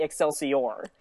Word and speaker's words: Excelsior: Let me turn off my all that matters is that you pronounce it as Stan Lee would pronounce it. Instead of saying Excelsior, okay. Say Excelsior.